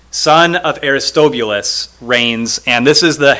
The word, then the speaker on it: Aristobulus: Aristobulus was Herod the Great's son, but Herod the Great had Aristobulus executed. I know I said that two different ways, but I think Aristobulus is the correct pronunciation.